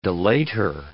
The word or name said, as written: delator